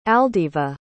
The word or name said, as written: aldiva